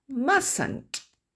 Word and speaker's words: mustn't: Must not a une contraction qui fait que le T qui est ici en gris ne se prononce pas. Et donc, d'ailleurs, on ne le retrouve pas en tant que son dans la phonétique. Et on entend mustn't.